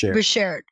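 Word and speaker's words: Beshert (באַשערט): I think in a lot of ways, you know that Yiddish expression, I hope I'm saying it right, Beshet, am I saying that right? – Beshert. – Yeah.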